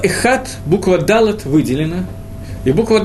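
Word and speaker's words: Эход (אחד): Принято, когда мы читаем слово «эхат», на букве «далит» немножечко растянуть эту букву. «Эход», тянуть ее настолько, насколько это можно, но так, чтобы произношение не испортилось.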